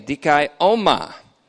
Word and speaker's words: dikaioma: The Greek looks like this, D-I-K-A-I-O-M-A, dikaioma. English translation, dikai, long O-M-A, dikaioma.